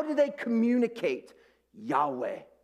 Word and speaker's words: Yahweh: Below that is the English word, the English letters, YHVH, and it would have been pronounced Yahweh, Yahweh.